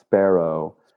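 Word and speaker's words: Spero: I guess it's pronounced Sparrow. – Sparrow. – Or really you should roll the R, but I mean, like, I don't even know.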